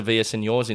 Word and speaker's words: Villaseñor: I think it's a trend now I saw on Melissa Villaseñor's Instagram.